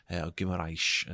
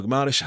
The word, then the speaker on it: Guimarães: Guimarães, as they keep saying in the Premier League, but I prefer my pronunciation, if I'm being honest.